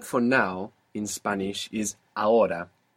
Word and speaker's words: ahora: This is spelled A-H-O-R-A. A-H-O-R-A. Ahora. – Ahora. – Good. – Is the H silent in every Yeah, unless it's C-H, in which case it's like English, ch.